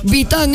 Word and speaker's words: Vitane: O sea, se escribe Vitane. Con V, Vitane. Okay. Se pronuncia champú Vitam. Encuéntrala en supermercados y perfumerías. Vitam es Recamier.